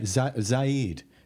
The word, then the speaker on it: Zaid: Now, I've known you for about 10 years and I always presumed your surname was pronounced with kind of two syllables, but I've never checked. – Yeah, you'd be right. – Zaid? – Yeah, Zaid.